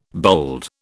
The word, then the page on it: bold: British English